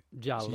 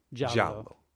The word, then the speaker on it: giallo: The original is the Dario Argento 1977 classic, which is an Italian giallo, which I actually said that wrong. I said that with a Portuguese accent. – Giallo. – So you say giallo.